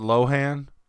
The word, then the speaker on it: Lohan: And they hired Lindsay Logan to do a Pilk commercial for them. – Well, I think she pronounces it Lohan, but that's...